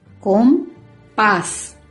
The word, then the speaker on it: compás: Compás. Se carga la voz en la última sílaba y termina con la letra S.